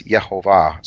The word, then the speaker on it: Yehovah: And, it, you know, it's Yehovah, so there are three syllables.